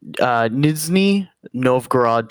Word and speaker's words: Nizhny Novgorod: Nizhny Novgorod Torpedo.